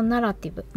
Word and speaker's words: ナラティブ: 死と再生のナラティブ、物語と書いてナラティブと読むんですが、中国古代の神話とデザインという企画展です。